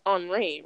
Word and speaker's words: Henri: I didn't know until like the other day that, okay, I'm gonna say this wrong because I think it's pronounced Henry Bendel, but it should be pronounced Henri, right? Like that's how it's said, that word.